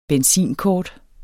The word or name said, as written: benzinkort